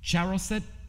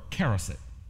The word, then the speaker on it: charoset: You have, I think this can be pronounced in two ways, charoset or caroset.